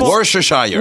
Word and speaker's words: Worcestershire: C'est Worcestershire. – Worcestershire. – C'est bon, pas Worcestershire. – Worcestershire, je pense. – Tu prononces pas le T.